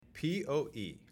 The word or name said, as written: PoE